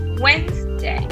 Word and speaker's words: Wednesday: Cuidado con Wednesday porque se escribe Wednesday. Y se escribe en tres sílabas, pero se pronuncia en dos. Wednesday.